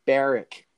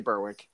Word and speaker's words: Berwick: It's pronounced Berwick. – Yeah, but no one says that. We all say Berwick.